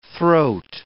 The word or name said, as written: throat